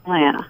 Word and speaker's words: Atlanta: Leave out that second T. It's always Atlanta.